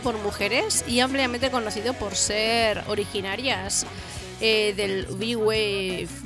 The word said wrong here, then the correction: New Wave: New Wave. – Perdona, por mi pronunciation.